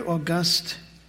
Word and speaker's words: august: The word august, children, spelled the same as the month of August, but pronounced differently.